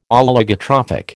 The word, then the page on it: Oligotrophic: American English